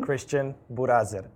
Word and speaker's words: Kristijan Burazer: Kristijan Burazer. Okay. so it's the pronunciation is a little bit different. Um, because it's you know European, so I know the R's are very difficult for uh, Americans.